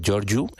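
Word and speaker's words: Gheorghiu: Ahí sonaban las voces de Roberto Alagna y Angela Georgiu, o Georgiu, como se pronuncia en rumano, el apellido de esta soprano, que bueno, en el momento en el que grabaron este registro discográfico, pues eran pareja, eran matrimonio.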